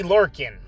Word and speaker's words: Lorcan: And uh, Oney Lochran or Lorcan. Oney Lorcan.